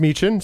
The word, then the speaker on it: Michin: So it is Meechan, pronounced Meechan, spelled M-I-C-H-I-N for you guys to know.